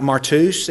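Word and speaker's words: martus (μάρτυς): It's pronounced something like martus. It's the same word as martyr.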